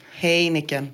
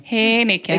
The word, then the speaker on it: Heineken: Talar nederländska flytande och vet hur man beställer en Heineken med korrekt uttal. Kan man få höra? – Heineken. – Heineken.